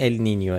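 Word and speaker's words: El Niño: ولی تلفظ درست اگه میخواین ال نینیو، ولی همه جا تو فارسی به چرخید کسی چیکی نمیشه ال نینیو، همه جا نوشتن ال نینو.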